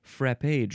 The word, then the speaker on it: frappe: And you can also pronounce it frap.